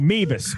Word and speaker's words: Meavis: And a kid by the name of Beavis. – Oh, no. – No, Meavis. Meavis. Yeah. That rhymes with Beavis.